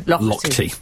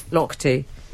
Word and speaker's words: Lochte: It's Ryan, how do you say that man's name? – Lochte. – Lochte.